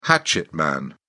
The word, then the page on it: hatchet man: British English